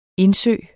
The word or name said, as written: indsø